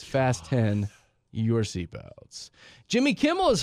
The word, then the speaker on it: Fast X: But they don't want you to pronounce it as Fast X. They want it to be pronounced as Fast 10.